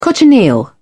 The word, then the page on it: cochineal: British English